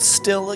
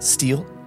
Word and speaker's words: still: Two, how do you say the word still again? – Still? – But you were like steel. – Steel? Like, yeah. – Did I say like, steel? – I'm steel not.